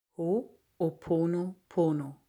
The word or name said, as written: Hooponopono